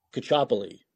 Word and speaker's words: Caccioppoli: Cacciopoli.